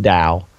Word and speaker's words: Tao: That's with a T, everyone. T-A-O. It's pronounced Dao.